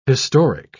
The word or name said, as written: historic